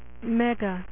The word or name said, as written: mega man